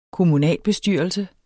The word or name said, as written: kommunalbestyrelse